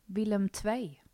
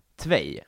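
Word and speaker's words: Willem II: Willem Tvej. – Tvej. Ja, det är mm. två då på holländska.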